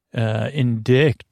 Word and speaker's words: indict: Indict. Yeah, you'll indict my spelling. Indict. I-N-D-I-C-T. I guess because it doesn't have, you don't pronounce the letter C in this word.